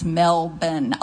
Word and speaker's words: Melbourne: It's Melbourne. I'll never forget her saying that. And so when you say that, I went to Melbourne, Australia. Most people think you're saying it wrong, but it's like Sherbert.